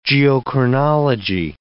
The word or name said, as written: geochronology